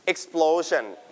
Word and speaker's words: explosion: It's called explosion. Listen to the audio. – Explosion. – One more time. – Explosion. – Your turn. Explosion. Stress on plo, explosion.